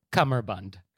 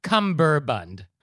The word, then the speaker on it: cummerbund: I'm looking at a maroon cummerbund and necktie. – Okay. So, first of all, the correct pronunciation is cummerbund. – Oh, really? – C-U-M-M-E-R-B-U-N-D. Yes, it's not cummerbund or cummerbun. It's cummerbund.